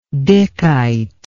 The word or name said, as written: dickite